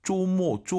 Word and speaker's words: 周末: 쪼모 쪼모 알아듣죠? – 能听懂. – 오유는 어우예요. 그리고 두 번째 글자는 모어 어가 들려야 됩니다. 잘 들어보세요. – 쪼모. – 한 번 더. – 쪼모. – 여러분 따라하셔야죠. 쪼모 아니고 쪼우 쪼모.